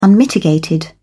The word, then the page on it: unmitigated: British English